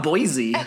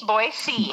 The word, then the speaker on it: Boise: And at least you say it right? It's not Boise. – Oh, yes. Boise. – Hard S.